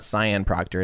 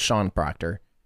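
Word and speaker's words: Sian Proctor: But also, if I remember right, one of them is actually, I think, I was corrected that it's not Cyan Proctor. It's Sean, it's pronounced Sean Proctor.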